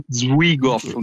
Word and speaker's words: Zwigoff: In cui è bellissimo perché è prodotto, ecco qui vado a sbagliare, vado a sbagliare la pronuncia, da Terry Zwigoff. – Sì, sì, no, credo si dica così. È giusto.